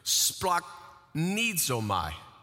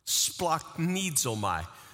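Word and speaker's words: Splachnizomai (σπλαγχνίζομαι): This Greek word, I'm gonna see if I can pronounce it for you. Splachnizomai. Splachnizomai. Don't try that until you're alone, because I don't want you passing any particles, all right?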